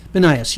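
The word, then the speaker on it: Banias: How do you pronounce that? But the town is Benias? – Benias, yeah.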